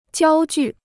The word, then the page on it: 焦距: jiāo jù